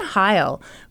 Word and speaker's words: Hile: Brian Heil, whose last name is unfortunately pronounced exactly like the Hitler salute, was living in Fremont, Michigan.